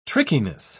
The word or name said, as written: trickiness